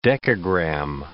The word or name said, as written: decagram